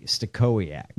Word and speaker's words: Stachowiak: S-T-A-C-H. – Stachowiak. – O-W-I-A-K, Stachowiak. – Go fuck yourself. – I'm pretty sure that's probably how you pronounce it. Stachowiak. I think it's probably a hard K.